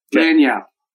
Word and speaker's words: lagniappe: We talked about the term lanyap. – Yes. Tell us what that is. Like, spell that for me. – Don't worry about it. It's spelled LAGNIAPPE. Lanyap.